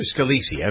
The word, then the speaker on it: Scalise: Is it Scalise or Scalise? I've forgotten how to pronounce that. It's Scalise.